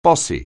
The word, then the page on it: bossy: anglais